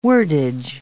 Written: wordage

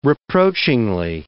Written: reproachingly

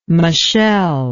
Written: machel

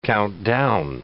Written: count down

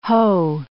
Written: ho

